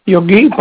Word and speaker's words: Yogi: Yogi Bhakta. – How do you spell that? – Y-O-G-I.